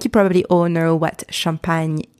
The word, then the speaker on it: champagne: Two syllables. Champagne. Repeat after me. Champagne. Champagne. So first syllable, the letters C-H-A-M are pronounced champ. Champ. So you've got the CH, the letter CH, pronounced sh, sh. And you've got the nasal sound en.